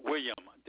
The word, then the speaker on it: William: My first name is spelled William, W-I-L-L-I-A-M. Last name is Morris, spelled M-O-R-R-I-S.